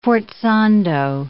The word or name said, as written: forzando